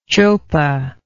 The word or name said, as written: chopa